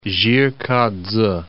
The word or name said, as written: Jih-k'a-tse